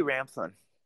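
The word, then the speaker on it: Ramthun: Had the opportunity to talk to Representative Hamthorn. Is that how you pronounce his name? – Timothy Ramthorn.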